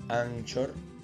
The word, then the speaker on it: Anchor: Eh, para los que no saben, eh, en español se escribe Anchor, así como se pronuncia Anchor y pueden esto, bajársela para escuchar el programa Salvador de Noche.